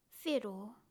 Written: whero

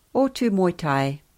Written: otumoetai

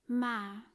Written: ma

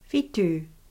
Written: whitu

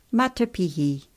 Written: matapihi

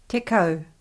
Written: tekau

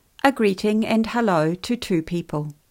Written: tena korua

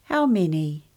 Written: How many

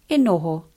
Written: e noho

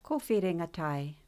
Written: Kowhiringa tae

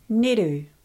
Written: Ngeru

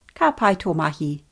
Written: ka pai to mahi

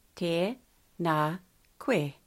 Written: tena koe

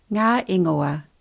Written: nga ingoa

